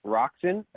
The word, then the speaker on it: Roczen: Now I have some notes here on Roczen.